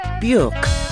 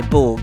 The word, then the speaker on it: Björk: I've just had lessons in how to say this name. Björk. Björk. Björk. I always say Borg or Björg or I don't know.